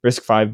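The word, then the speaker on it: RISC-V: I don't know actually how you say it, but I think it's five. Yeah. – Is it five? Okay. RISC V boards and stuff too.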